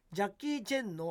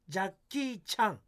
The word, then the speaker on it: ジャッキー・チャン: 3 位ジャッキー・チェンの正確な発音はジャッキー・チャンへ